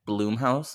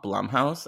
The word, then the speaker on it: Blumhouse: One time I was on a podcast with Joe Lipsett and I pronounced it Bloomhouse and not Blumhouse, and he served me my tush and I deserved it.